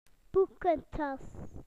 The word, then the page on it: schoolbag: British English